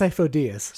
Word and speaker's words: Sifo-Dyas: It's Sifo-Dyas. – Sifo-Dyas? – Yes.